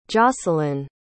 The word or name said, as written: josalynn